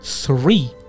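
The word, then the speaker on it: three: مثلا نگید تیری، مثلا اینجا یه نفر میخواد بگه سه بعد بگه تیری، خب این تیری غلطه، این پرونانسیشن اشتباه هست. چرا؟ چون که تی ایچ تلفظ میشه ث، ثری باید تلفظ بشه ثری نه تیری.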